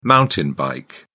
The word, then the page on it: mountain bike: British English